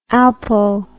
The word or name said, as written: alpo